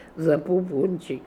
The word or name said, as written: Zapopoonchik